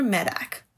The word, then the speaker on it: Medak: Did I say his name? – I don't know. Never heard of him. – M-E-D-A-K. Peter Medak. – Medak.